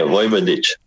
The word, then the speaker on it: Vojvodic: Yeah, Vojvodic.